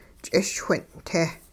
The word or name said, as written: ch'ischwin'-te